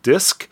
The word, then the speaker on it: Disq: This is a band called Disc. It's spelled D-I-S-Q.